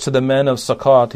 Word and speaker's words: Succoth: Succoth is how you'd pronounce it, I believe, in Hebrew. This town, Succoth, was right on the eastern side of the Jordan River, not too far away from the Jordan River.